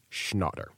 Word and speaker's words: Schnatter: I've learned how he pronounces the name, Schnatter.